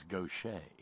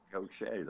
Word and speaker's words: Gautier: From Gautier, Mississippi, which I, by God, know to pronounce as Gaucher. – Gaucher, that's right. That's right. That is correct.